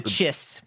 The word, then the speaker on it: Chiss: So it'll be interesting to see if we get any of the, how do you say, is it the Chiss? I forget how to say the Chiss.